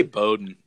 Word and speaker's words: Bowden: They say Bowden. Yeah. Bowden. Okay. Tennessee fans, I think, have a hard time with that because it's spelled the exact same way as Bowden.